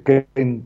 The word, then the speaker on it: Keen: Carlos Ken. Este, Ken, que se escribe Kin, ¿no? K-I eh, K-E-E-N.